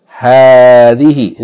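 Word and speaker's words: hadhihi (هذه): So it's going to be pronounced hadhihi. In the same way that we said about hadha. The alif is not written, but it should be pronounced the first syllable long.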